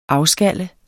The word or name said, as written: AFSKALLES